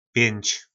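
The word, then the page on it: Pjync: nářečí Po našimu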